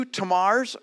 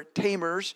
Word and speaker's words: Tamar: Now, all Hebrew words have the emphasis on the last syllable. So in Hebrew, and I'm not great at Hebrew, but I think this would be pronounced Tamar at the end. We kind of would say Tamer, putting the emphasis on the first syllable.